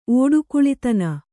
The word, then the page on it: ಓಡುಕುಳಿತನ: ōḍukuḷitana